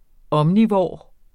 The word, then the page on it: omnivor: ˈʌmniˌvɒˀ; ˈʌmniˌvoˀɐ̯